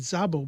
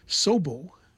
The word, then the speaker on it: Szabo: I always pronounce it Szabo, but evidently the traditional way of pronunciating the name is Szabo at the guitar, Rude Jacobs at the bass, and Gilberto Cupini at the drums.